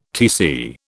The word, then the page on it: Tc: British English